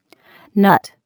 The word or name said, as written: nut